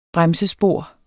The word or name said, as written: BREMSESPOR